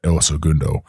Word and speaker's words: El Segundo: I've been out there, but I don't think I've ever been to El Segundo.